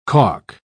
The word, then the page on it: calk: British English